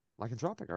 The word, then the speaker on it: lycanthropic: It's like I can read it, I don't know how to say it. Let me see. Lycanthropic. – Lycanthropic. – Oh, cool. – Okay. – Lycanthropic, all right.